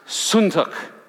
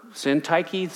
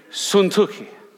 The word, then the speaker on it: Syntyche: He says in this next portion, he says, I beseech Euodias, and I beseech, and that word is pronounced Suntuk. Just so you know, many of you have read that over in Syntyche, Syntyche. It's pronounced in the Greek, Suntuki. Euodias and Suntuki, however you say it, that they be of the same mind in the Lord.